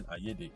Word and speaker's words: Ayede: And then Ayede is AYEDE.